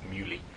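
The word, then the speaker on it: Muhly: I found out how to pronounce it. It's Muley.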